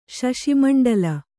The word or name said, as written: ಶಶಿಮಂಡಲ